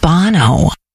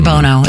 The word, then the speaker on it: Bono: Dolly Parton's final recording is a duet with Bono. You say Bono or Bono? It's Bono, right? – I would say Bono because of how it's pronounced.